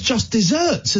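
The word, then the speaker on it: just deserts: I thought it was just desserts, as in Knickerbocker Glories. – Wow. – Why would it not be? It's pronounced just desserts.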